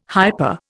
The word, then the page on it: hyper: Indian